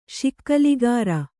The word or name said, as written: ಶಿಕಲಗಾರ